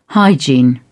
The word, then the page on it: hygiene: British English